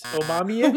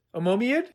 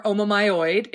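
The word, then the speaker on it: omomyid: Uh, I pronounce I pronounce it Omomiad um, or omomyoid, if you talk to some folks in certain parts of the field.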